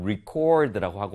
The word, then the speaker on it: record: 그래서 이렇게 같은 철자 RECORD 동일한데도 불구하고 어떻냐면, 이 품사에 따라서 동사일 때, 명사일 때 발음이 다릅니다. 동사일 때는 record, 뒤에 강세가